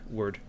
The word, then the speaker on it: wyrd: Hellboy, Web of Weird. – Word. – It's word. That's what you want me to say, right? – Yep. What is weird about that word is that it's spelled. – It's spelled W-Y-R-D.